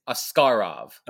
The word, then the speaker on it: Askarov: I believe it's Askarov. Like, I think the emphasis is on the second syllable.